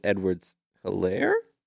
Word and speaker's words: Edwards-Helaire: But it is Clyde Edwards-Helaire? – Correct.